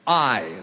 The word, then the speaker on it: Ai: Now Joshua sent men from Jericho to Ai. That's how you pronounce that little town, Ai,